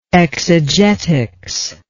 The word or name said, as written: exegetics